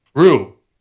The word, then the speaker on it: Ryu: Ryu.